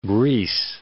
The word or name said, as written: Greece